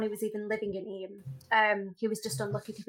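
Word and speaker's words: Eyam: And it's called Eam. That's how you pronounce it.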